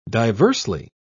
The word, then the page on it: diversely: British English